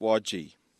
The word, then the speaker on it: Widgie: Why spell a place as Widgie and then pronounce it Wodgie?